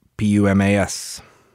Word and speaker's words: Pumas: P U M A S. the Pumas. No, just like the puma, the animal, spelled the same way.